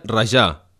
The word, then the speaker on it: rajar: Aclarim primer que el verb rajar, pronunciat a la catalana, seria rajar, que és un verb ben genuí i, per exemple, en fem frases com d'on no n'hi ha, no en raja.